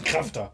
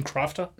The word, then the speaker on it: Crafter: Muss man auch ganz wichtig, das muss man deutsch aussprechen. Das ist ein Krafter. – Ein Krafter. – Nicht ein Crafter? – Nee, ist ein Krafter.